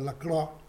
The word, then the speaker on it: Lacroix: Inmediatamente el señor Zinedine Zidane, actual técnico del equipo francés, puso a Maxence Lacroix, Lacroix se escribe por si acaso, pero se pronuncia Lacroix, jugador del Chelsea de 26 años, compañero de Monchito Caicedo, entró a ocupar esa posición de defensa, ¿no?